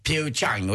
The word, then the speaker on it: Pyeongchang: Eh, men man uttalar det som eh, Pyeongchang och det är 2018 alltså.